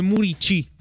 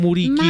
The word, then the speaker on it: Muriqi: Si scrive Muriqi, ma si legge Muricci, non Muriqi.